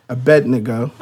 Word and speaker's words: Abednego: And it says that in this passage of scripture, Daniel was captured along with the three Hebrew boys, Shadrach, Meshach, and Abednego. – Abednego.